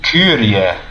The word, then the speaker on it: Kyrie: So Kyrie? – Kyrie. – Kyrie. – Yeah, that's the original way of pronouncing it.